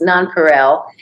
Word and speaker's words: nonPareil: I was pronouncing it wrong. Listeners, it's nonPareil, and it is nonPareil Institute. So sorry about that. Go ahead. – No, that's fine. It's sometimes hard to say. The French pronounce it much better than I do, nonPareil, but sometimes saying it and spelling it's a challenge.